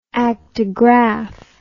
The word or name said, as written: actigraph